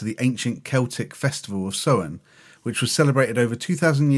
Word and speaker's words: Samhain: I'm led to believe that Samhain is correct, even though the spelling suggests it should be Samhain. It's spelled S-A-M-H-A-I-N.